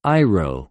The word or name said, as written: αἰρω